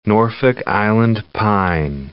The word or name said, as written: Norfolk Island pine